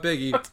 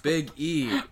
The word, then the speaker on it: Big E: Not Biggie, Big E. – Okay. – You're putting the emphasis in the wrong syllable, Brendan.